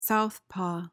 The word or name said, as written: southpaw